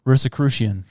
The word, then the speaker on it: Rosicrucian: Is it Rosicrucian? – Yep, that's it. Rosicrucian. – Dang it! – One more time in a deep voice. – The Rosicrucians.